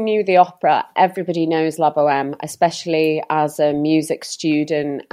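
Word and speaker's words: La Bohème: It's La Boheme.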